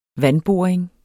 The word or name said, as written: vandboring